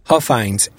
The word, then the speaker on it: Huffines: Huffines, H-U-F-F-I-N-E-S, age 53.